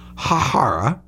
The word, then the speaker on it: Bharara: I am mispronouncing the U.S. attorney's name there, but it's Preet Bharara, blasting Silver.